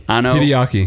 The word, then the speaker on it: Anno Hideaki: Godzilla, Ultraman, Kamen Rider join forces in Shin Japan Heroes universe from rival studios in Anno Hideaki. What did we say his name was? – Anno Hideaki.